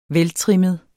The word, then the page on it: veltrimmet: -ˌtʁεmˀəð; -ˌtʁεməð